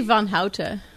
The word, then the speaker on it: Van Houten: Van Hout. Did I say it correctly? I didn't say it in the Dutch way. – It's actually Van Houten.